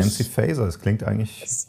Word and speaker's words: Faeser: Die hessische Fraktionschefin Nancy Faeser, spricht man das so aus? – Ich glaube, ehrlich gesagt, man spricht sie Faeser aus. Also sagen wir mal alle Faeser, aber es ist ja nicht Englisch.